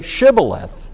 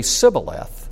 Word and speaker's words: Shibboleth: And then the second one, which is related to the Hebrew letter that we get, and it can be pronounced S or Sh, but it's the same Hebrew letter. And we, by the way, interesting story on that Hebrew letter. It can get you killed. There's a story in the book of Judges about people not able to pronounce it a certain way. And we know how to pronounce these letters by Jewish tradition. And so they weren't able to say Shibboleth, and they only could say Sibboleth.